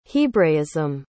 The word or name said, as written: hebraism